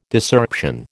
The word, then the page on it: Discerption: American English